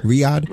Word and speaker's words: Riyadh: And some fan tweets out, I don't know if I'm pronouncing it right, is it Riyadh? Is that how you pronounce their city? – Riyadh. – Okay, so Riyadh. Right.